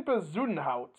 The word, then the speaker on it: Bezuidenhout: Christian Bezuidenhout.